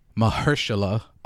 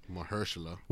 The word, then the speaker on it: Mahershala: Mahershala Ali. – Mahershala.